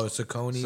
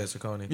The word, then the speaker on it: Saucony: Is it Saucony or? – Oh, Saucony. – Saucony. – Yeah, Saucony. – Yo, those, he put up a pair of those and it was like tan with pink and blue.